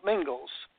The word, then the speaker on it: Mingils: So for those people that want to date this and kind of get the accuracy here, my last name is spelt Mingles. M-I-N-G-I-L-S, how else would you spell it?